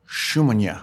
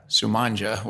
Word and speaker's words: Sumonja: It is Drago Sumonja, all right? But I was going to say Sumonja, which is the Americanized version that he gets.